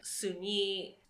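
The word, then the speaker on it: Soon-Yi: Suni, that's how Mia pronounces it, Suni was, like, dead to her.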